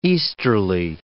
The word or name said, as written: easterly